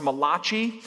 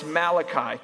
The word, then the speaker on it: Malachi: Against popular demand or popular opinion, it's not pronounced Malachi. It's Malachi chapter 3, verses 6 through 12.